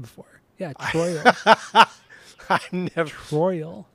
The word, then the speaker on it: Troyal: He was born Troyal Garth Brooks. So his first name is spelled T-R-O-Y-A-L. – Troyal? – Troyal, yeah.